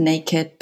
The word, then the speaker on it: NAKD: You mentioned the name that you pronounce it naked. It's spelled N A K D.